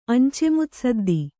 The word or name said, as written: ಅಂಚೆಮುತ್ಸದ್ದಿ